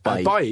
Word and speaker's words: Bailly: How do you pronounce it? – Yeah. Bailly. Bailly's fine. He won't mind, will he? – We all like a Bailly. – Just say it as if you're falling down a well.